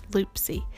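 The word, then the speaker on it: Loopsie: It's L-O-O-P-S-I-E, Loopsy. It's free. Now with it, and it's available on Apple and Android. All these are that I'm mentioning today, by the way. So with Loopsy, it's sort of like it's video, and then they take that video and make a picture.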